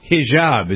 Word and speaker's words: hijab: a swimsuit that has a hijab.